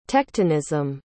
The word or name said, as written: tectonism